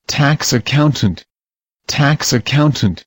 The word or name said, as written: tax accountant